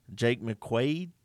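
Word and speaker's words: Jake McQuaide: Our long snapper, Jake McQuaid, am I saying that right? Sounds right.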